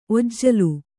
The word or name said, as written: ಒಜ್ಜಲು